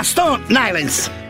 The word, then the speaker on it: Stone Island: Questi jeans della Stone Ainsen. – Di Jim, che c'è la... – Questi jeans della Stone Islands. – Ma perché scrivono come si legge? – Si chiama così, Stone Island.